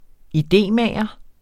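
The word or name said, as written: idémager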